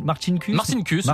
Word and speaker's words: Marcinkus: Alors comment on prononce Paul Marcinkus ? – Marcinkus, voilà, il était d'origine lituanienne, mais il était américain.